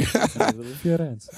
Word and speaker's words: Firenze: I want to go to Firenze. – Oh, yeah? You said it perfectly. – Firenze.